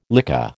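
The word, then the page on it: lycée: American English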